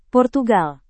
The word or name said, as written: portugal